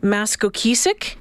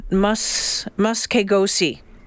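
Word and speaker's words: Maskekosihk: I thought it was Maskokeesik. Because that's how I sounded it out. It is spelled Maskokeesik. HK, okay. – Maskokeesik Trail. I just Googled, and, you know, that's all you have to do, but I just never did. It's actually said Muskegosi.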